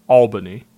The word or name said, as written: Albany